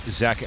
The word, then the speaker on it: Zach: I'm, of course, your illustrious host, Zach Evergreen.